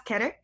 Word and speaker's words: Keter: Are we pronouncing that like the Hebrew? – We are now. – All right, cool. Object class Keter.